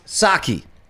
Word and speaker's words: Psaki: Psaki is pronounced Saki. – Oh, see, the P is silent. – Okay, so it is Saki. – I wondered. – Like the drink.